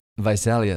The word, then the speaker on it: Visalia: I think I'm saying that right? – Visalia. – Visalia. Okay, thank you.